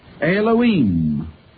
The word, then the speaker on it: Elohim: Elohim. And the I am, pronounced as im, in the Hebrew language, pluralizes the name, and there it is the name of more than one person.